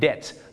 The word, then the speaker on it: debt: debt ต้องออกเสียงว่า debt I'm in debt ไม่ใช่ debt นะฮะบ้านเราชอบออกเสียง debt ว่า debt ไม่ได้นะครับ